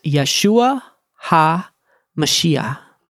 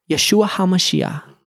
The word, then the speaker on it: Yeshua Ha Mashiach: In Hebrew, you would pronounce Jesus the Christ as Yeshua Ha Mashiach. Yeshua Ha Mashiach. Ha means the, and Mashiach means the Messiah, the anointed one.